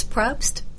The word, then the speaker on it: Propst: It's Propst. – Propst? – Yeah, you're good. – We're going P-R-O-P-S-T.